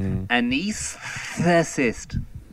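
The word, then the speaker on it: anaesthetist: There is a hidden S. It's anesthetist.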